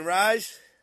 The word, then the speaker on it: Reis: So that was Shane Rise. – Shane Rise, new album called, what is it called? Vibe, I think, but it's spelled V-E-I-B because his last name is spelled R-E-I-S.